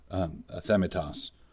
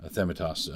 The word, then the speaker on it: athemitos: And that's what this word, athemitos, there, I said it right that time, athemitos, that's what this word is referring to.